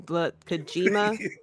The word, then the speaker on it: Kojima: I know it sounded closer to Coochie Mama than Kojima, so I've been tiptoeing around these names for New Japan.